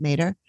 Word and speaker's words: Matter: Matter, but I heard someone else pronounce it Mater.